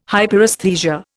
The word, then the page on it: Hyperesthesia: Indian